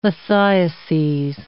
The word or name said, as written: lithiases